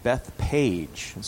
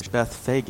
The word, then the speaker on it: Bethphage: Now, before I begin reading, there is a place name here, which, because it's so often mispronounced, when it was imported into the United States, usually the word was changed to Bethpage. So there are cities called Bethpage in America. The name of the city is Bethphage, is how it's pronounced.